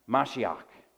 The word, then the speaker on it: Mashiach: So the Hebrew word, which is what the Old Testament was written in, this psalm was written in Hebrew, the Hebrew word for anointed there is pronounced Mashiach. You probably guessed where that we get our English word Messiah from, Mashiach. So that's what the word anointed sounds like in the Hebrew.